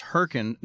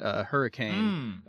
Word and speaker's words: Hurricane: Constantin said that West Virginia City is pronounced Hurricane, not Hurricane.